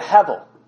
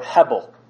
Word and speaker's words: hevel: It's the word hevel, or hebel. B's and V's in Hebrew are the same consonant. They just, based on the thing, are pronounced differently. So hebel or hevel.